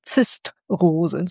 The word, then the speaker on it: Zistrose: Und da hat er gesagt, das ist keine Rose, das ist eine Zistrose. So wird sie ausgesprochen, sie gehört zur Familie der Rosenartigen, hat aber keine Stacheln.